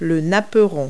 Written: doily

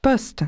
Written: post

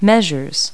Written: measures

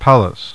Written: Pallas

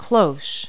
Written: cloche

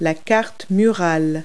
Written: wall map

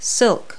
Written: silk

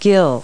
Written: gill

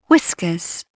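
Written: whiskers

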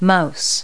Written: mouse

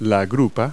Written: croup

